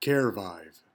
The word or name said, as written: carevive